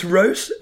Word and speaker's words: Reus: This is, of course, the game, R-E-U-S, is that pronounced Rouse, is that right? – Yes, it's Rouse. It's basically the, it's basically the Dutch name for giant.